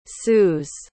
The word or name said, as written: saults